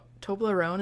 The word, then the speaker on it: Toblerone: And this is where we find the big Toblerone.